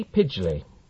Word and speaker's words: Pidgley: Pidgley. – Pidgley. Could you spell that for me, please? – P-I-D-G-L-E-Y. – P-I-D-G-L-E-Y.